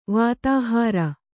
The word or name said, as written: ವಾತಾಹಾರ